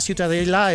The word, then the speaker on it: Ciutadella: Para mí la Ciutadella es...